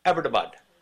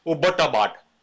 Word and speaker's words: Abbottabad: I would like to encourage you to take upon you the pronunciation of Abbottabad. Watch my lips closely. Watch how the syllables roll from my tongue like pomegranates at the time of harvest. Abbottabad. Not Abbottabad or Ubbottabad.